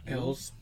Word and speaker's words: Elwes: How do you pronounce his last name? – Elwes. – Elwes? – Elwes? – Elves? – I said Lewis, so I was completely off.